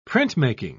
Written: printmaking

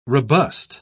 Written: robust

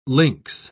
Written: links

